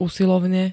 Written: usilovne

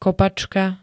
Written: kopačka

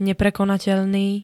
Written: neprekonateľný

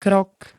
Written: krok

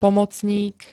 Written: pomocník

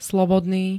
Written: slobodný

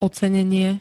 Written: ocenenie

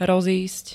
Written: rozísť

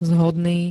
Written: zhodný

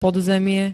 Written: podzemie

